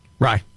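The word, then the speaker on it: Rai: Rye.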